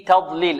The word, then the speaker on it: تضليل: في تضليل، التاء هذه ماذا؟ مرققة والضاد مفخمة.